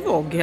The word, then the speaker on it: Vogue: För att jag tror att det heter Vogue helt enkelt. Ja. Alltså, det är ju ett franskt ord som du nu uttalar på ett engelskt sätt.